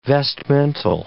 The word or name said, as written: vestmental